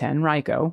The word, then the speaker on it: Henrico: Just wanted to say that Henrico County is pronounced Henrico, which we learned yesterday as well.